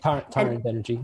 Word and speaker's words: Tarun Banerjee: Taran Banerjee.